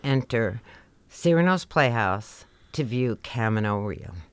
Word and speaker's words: Camino Real: The name of the play, Camino Real by Tennessee Williams, is indeed pronounced Camino Real. Unlike the Spanish Camino Real, which means royal road, Camino Real is where the royal road meets the real road.